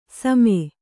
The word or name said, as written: ಸಮೆ